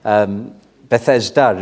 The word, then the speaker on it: Bethesda: You'll have to forgive my pronunciation. Bethesda is how we say it in Welsh.